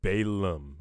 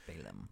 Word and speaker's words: Balaam: So first thing I want to talk about is the doctrine of Balaam. I'm saying that right, yeah? – Yeah. – I keep, Balaam.